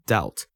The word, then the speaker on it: doubt: 那要注意这个字中间的 b 也是不发音的，它的拼法 d o u b t。那跟之前讲过的 obvious 一样哦，这个字念成 doubt，中间的 b 是不发音的。